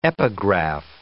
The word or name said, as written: epigraph